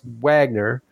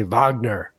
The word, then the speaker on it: Wagner: Perry, in the USA, my name is pronounced Wagner, but I'm fishing in Germany. They say Wagner. Either is acceptable, and I must say you nailed my annoying Midwestern nasal accent to a T.